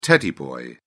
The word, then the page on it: teddy boy: British English